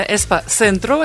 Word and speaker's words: Эспа-центру: Кстати, он произносится именно Эспа-центру. – Эспа-центру. – Да, испанцы не умеют говорить звук «ц».